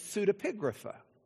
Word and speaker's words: pseudepigrapha: I had someone on staff, wasn't Brent, wasn't Jared, ask me, exactly how do you pronounce that word? It's pseudepigrapha.